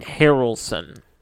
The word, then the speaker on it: Harrelson: Was this pronounced Harrison or Harrelson? – Harrelson. – Yes.